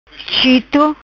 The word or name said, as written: Chito